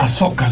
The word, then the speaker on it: Ahsoka: Oigan una cosa, hay una serie que se llama Ashoka. ¿Se dice así, señor? – Ashoka, sí.